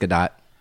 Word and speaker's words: Gadot: Apparently it's pronounced Gadot.